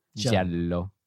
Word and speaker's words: giallo: Giallo, return to. We looked up the proper pronunciation. Giallo. – That's right. – Giallo.